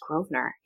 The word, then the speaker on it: Grosvenor: That's kind of how you spell it, but it's definitely not how you say it. – No. Uh, it's just Grosvenor.